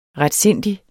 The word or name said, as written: retsindig